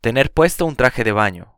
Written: tener puesto un traje de baño

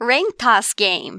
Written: Ring toss game

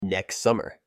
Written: next summer

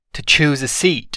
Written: to choose a seat

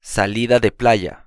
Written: salida de playa

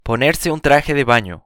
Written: ponerse un traje de baño

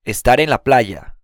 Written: estar en la playa

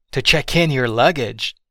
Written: to check in your luggage

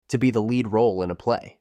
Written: to be the lead role in a play

